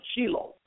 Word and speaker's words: Shiloh: You know, Christians say Shiloh, but in Israel they pronounce it Shiloh.